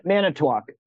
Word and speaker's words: Manitowoc: Manitowoc.